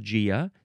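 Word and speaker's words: Gaea: Now, maybe some people want to say Gia or Gaia, Gaia. But it is officially pronounced Gia, spelled with a capital G-A-E-A. I think we will see Gaia later, G-A-I-A.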